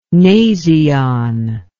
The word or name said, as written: nasion